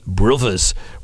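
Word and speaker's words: Bruvvers: Joe Brown and the Brothers with the two V's. Two V's because he was a Cockney, and that's how they say it down there.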